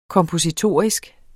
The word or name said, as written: kompositorisk